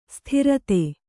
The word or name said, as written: ಸ್ಥಿರತೆ